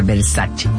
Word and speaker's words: Versace: Por ejemplo, Versace, cuando la marca Versace es italiana, entonces tiene que pronunciar Versace.